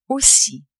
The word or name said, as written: aussi